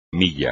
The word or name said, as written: milla